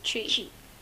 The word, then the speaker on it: Twi: Tree. It's spelled T-W-I, but it's pronounced tree.